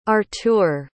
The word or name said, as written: artur